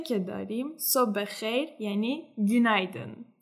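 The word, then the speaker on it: günaydın: خب میشه اینو برامون بازش کنی این گون با یو هستش درسته با یو که دو نقطه بالاش داره پس اینو باید ما او تلفظ کنیم هم ای گونلر و هم گونایدن با او تلفظ میشه درسته درسته تلفظت خیلی درسته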